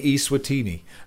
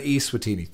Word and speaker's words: Eswatini: I just listened to your Football Weekly podcast mentioning Eswatini. It's pronounced Eswatini, as in E-H, rather than Eswatini.